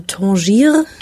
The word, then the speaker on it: Tanger: Tanger, wie, wie spricht man eigentlich? Tanger. Also ich glaube auf Deutsch einfach Und äh, ansonsten sagt man äh, Tangier. Tangier.